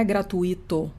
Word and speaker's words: gratuito: Não, não é gratuito. Porque não é hiato, é um ditongo. A pronúncia é gratuito.